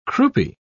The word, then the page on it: croupy: British English